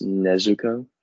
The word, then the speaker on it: Nezuko: His sister, is it Nezuko? Is that how you say it? – It's Nezuko.